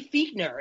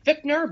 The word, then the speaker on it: Fichtner: Randy Fichtner, it is spelled phonetically Fichtner, but it is pronounced Fichtner, the quarterback's coach who played mediator between Haley and Roethlisberger during the season.